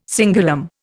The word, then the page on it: cingulum: Indian